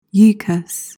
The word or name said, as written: yichus